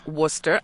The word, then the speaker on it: Wooster: And then went and met some of our listeners in Worcester, Ohio, spelled W-O-O-S-T-E-R, but pronounced Worcester, I was told, for WKSU, and it was great fun.